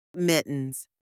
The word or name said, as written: mittens